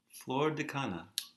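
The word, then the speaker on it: Flor de Caña: Flor de Cana.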